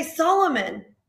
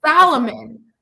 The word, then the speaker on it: Solomon: How do you not know how to say Solomon? – But I mean, it's not like he's mispronouncing like he's adding in a letter that's not even there. – Salomon. – Like, there's no A anywhere in his last name.